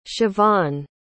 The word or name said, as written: Shevaughn